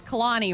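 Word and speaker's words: Kehlani: You said the girl's name wrong that he collabed with. Can you repeat the question? Sorry. – No, I'm not saying that name wrong again. – I got hung up on that. – Can you please, I beg you, producer Nicole, phonetically write names for me? – I know. I had to ask her earlier because I think it's Kalani, right? – Yep.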